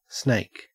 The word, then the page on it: snake: Australian English